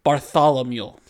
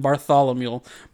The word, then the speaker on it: Bartholomew: Ooh, Bartholomew. – Yep, there you go. Just say it like Barthol and then think, oh, a mule's over there. Mm-hmm. Bartholomew. – So your friend Bartholomew.